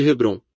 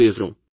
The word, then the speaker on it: Hebron: Quando a amada esposa de Abraão, Sara, morre na região de Hebrom, muitos de nós, em nossa tradição inglesa, a chamamos de Hebrom. Hebrom é na verdade pronunciado Hebron.